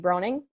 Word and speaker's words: Broening: I'm Kaycee Broning, and my name is spelled K-A-Y-C-E. My last name is B-R-O-E-N-I-N-G.